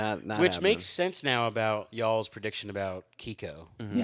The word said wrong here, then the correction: Keiko: Did I say it right? Kiko, or is it Keiko? – It's Keiko. – Pretty sure it's Keiko. – Keiko. Okay. – Yeah. – Yeah, my bad. I'm so sorry.